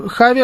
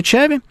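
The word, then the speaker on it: Xavi: Хави Эрнандес, он же Чави Эрнандес, на самом деле. Правильное произношение такое, поэтому не бейте меня, когда я буду иногда называть его Чави.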